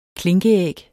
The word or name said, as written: klinkeæg